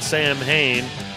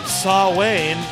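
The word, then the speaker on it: Samhain: And then he started Sam Hain, or Saw Wayne, if you want to pronounce it correctly.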